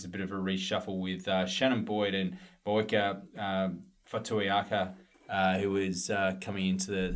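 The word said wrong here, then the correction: Fotuaika: Well, how would you pronounce it? – Fotuweka, I believe. – Fotuweka. – The accepted pronunciation.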